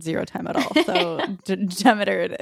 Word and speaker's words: Demeter: Is that how you pronounce it? I've been saying Demeter this whole time, but I have literally no idea. Demeter? – I've heard a whole range of them. Demeter is how I usually say it, but I took, like, Greek very, very, very briefly, and don't quote me on that.